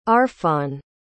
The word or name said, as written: arfon